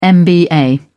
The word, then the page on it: mba: British English